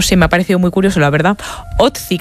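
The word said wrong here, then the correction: Ötzi: creo que se llamaba, un nombre muy, muy noruego. – Utzi, ¿no? – Pues sí, no sé, lo pronuncias tú mejor que yo. – Utzi, ah, vale.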